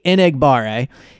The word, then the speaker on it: Enagbare: I looked up the pronunciation, I listened to him a bunch of times, that's the best I could do. I'm in playoff mode, no more mispronunciation. Kingsley Enagbare, so he will also be going up against against Lane Johnson, because he lined up 302 times on the left.